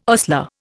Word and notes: ostler: Indian

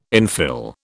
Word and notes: infill: British English

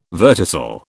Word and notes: verticil: British English

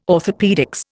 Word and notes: orthopaedics: Indian

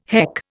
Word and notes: heck: Indian

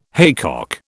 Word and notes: haycock: British English

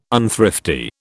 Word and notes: unthrifty: British English

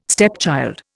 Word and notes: stepchild: Indian

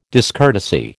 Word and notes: discourtesy: American English

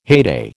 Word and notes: heyday: American English

